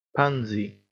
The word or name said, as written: pansy